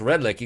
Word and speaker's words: Redlich: Or you can just Google him, Carlos Redlich. You can see the spelling on the screen right now with R-E-D-L-I-C-H, even though it's pronounced like a K, Redlich.